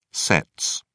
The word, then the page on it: sets: British English